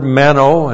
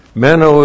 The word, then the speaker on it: meno: That's the word meno, and with John, he has a couple of words he uses over and over and over again.